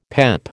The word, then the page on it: PEP: American English